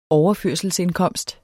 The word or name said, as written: overførselsindkomst